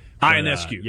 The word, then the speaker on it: Ionescu: Ionescu.